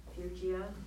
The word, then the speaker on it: Phrygia: Now one named Quintus of Phrygian, who was but lately come from Phrygia. What is the correct pronunciation? – Phrygia.